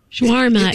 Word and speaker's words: shawarma: Shawarma? – Shawarma.